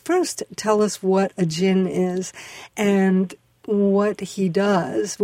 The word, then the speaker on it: Jinn: Jinn? Is that? – That's right. It's Jinn.